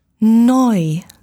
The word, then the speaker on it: Neu: The combination EU is pronounced OI. – Neu.